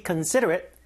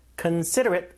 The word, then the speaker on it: considerate: You should be considerate. Considerate. 아, 발음이 어렵네요. Considerate. 약간 리을처럼 바뀌어요. 이게 flap sound, 솔타름.